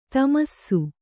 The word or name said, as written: ಥಮಾಸ